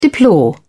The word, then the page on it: deplore: British English